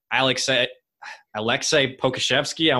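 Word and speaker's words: Aleksej Pokuševski: And I put Alexei Pokuševski. I want to say I'm pronouncing that right.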